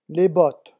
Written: les bottes

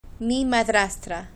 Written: mi madrastra

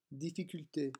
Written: difficulte

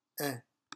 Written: in

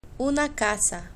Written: una casa

